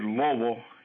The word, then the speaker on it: Louisville: Notice I said Louisville, and that's how you pronounce it.